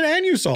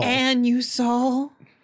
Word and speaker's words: Anusol: It's they pronounce it anusol. – Anusol. Anusol. – Like Uranus.